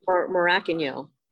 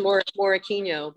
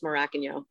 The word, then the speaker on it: Morokinyo: Tola Morokinyo is how you say his last name. – Oh, yeah. – Is I'm it really? How... – Yep. Everyone says Morokinyo, but it's Morokinyo.